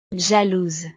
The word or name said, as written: jalouse